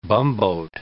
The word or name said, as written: bumboat